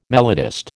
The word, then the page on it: melodist: American English